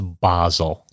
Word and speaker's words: Basel: I believe it's Basel.